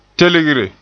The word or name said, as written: telgre